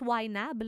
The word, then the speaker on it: YNAB: On le prononce YNAB, là, entre utilisateurs.